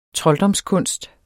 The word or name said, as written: trolddomskunst